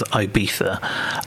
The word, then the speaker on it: Ibiza: She's had, I think, either three, might have even been 4 days over in Ibiza, which I'm going to pronounce it properly as Ibiza, unlike the Venga Boys coming up.